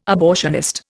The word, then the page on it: abortionist: Indian